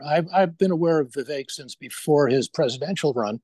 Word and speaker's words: Vivek: How do you pronounce uh, Vivek's name? Vivek rhymes with cake, Ramaswamy. Vivek Ramaswamy.